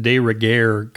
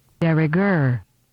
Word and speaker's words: de rigueur: It's not like I use the phrase often, but I've always pronounced de rigueur, kind of giving it a French flavor instead of de rigueur, de rigueur. But I looked it up as I was making this recording, and I found that the proper pronunciation is not nearly so elegant.